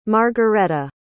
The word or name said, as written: margaretta